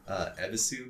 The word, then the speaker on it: Ebisu: They like don't pronounce the U. It's fucking Ebisu, dude. – All right. The U is silent? – In Japanese, it is, kinda.